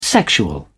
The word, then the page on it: sexual: American English